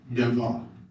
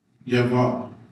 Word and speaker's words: Yahweh: Yahweh. Yahweh.